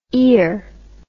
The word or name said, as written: eer